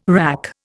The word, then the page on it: WRAC: Indian